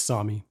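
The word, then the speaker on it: Sámi: On the pronunciation guide in Wikipedia, it said that the A in Sami was pronounced like the A in bra. So there you go.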